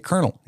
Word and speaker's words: colonel: You get to be a colonel. He says, I was a colonel. I earned this uniform. – It always throws me off. It's spelled colonel.